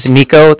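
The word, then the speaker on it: Niko: My name is Nico. That's N-I-K-O. I'm calling with the Bank of Cardiff, and Cardiff is spelled C-A-R-D-I-F-F, as in Frank, Frank, so it's like car and then different.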